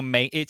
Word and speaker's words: Mencken: Mencken or Mencken, something like that? – Basically, H.L. Mencken. It's spelled Mencken, so it's like M-E-N-C-K-E-N, but it might be pronounced Mencken.